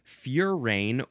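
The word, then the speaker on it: furane: It is just spelled F-U-R-A-N. Furan. Ooh, you can also spell it with an E at the end, and then it changes the pronunciation to furain or furain, because when you add the E, it makes the previous vowel long.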